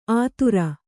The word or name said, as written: ಅದೂರ